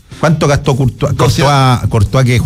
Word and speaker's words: Courtois: Courtois, ¿lo dije bien? – Sí.